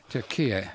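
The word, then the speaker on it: Türkiye: I'll attempt to pronounce it correctly. Turquia. – Turquia is correct.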